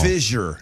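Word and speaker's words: Fischer: And you're looking at when we're talking about Mayor Pfister, we're talking about Greg Fisher. – Huh? – You're pronouncing it wrong. – Fisher, like anal Fisher. – No, Fisher, like you're fishing. You're fisherman. Greg Fisher.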